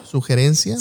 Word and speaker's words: sugerencia: alguna sugeración. – Sugerencia. – Sugerencia, perdón, siempre lo digo mal.